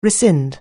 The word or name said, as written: rescind